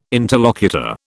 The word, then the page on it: interlocutor: British English